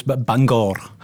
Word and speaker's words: Bangor: And we also got a very special guest joining us all the way from the shores of Bangor. Am I saying that right? – No, it's Bangor. – Bangor. – You got to roll the R's.